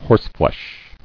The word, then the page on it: Horseflesh: horse·flesh